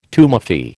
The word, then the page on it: tumefy: American English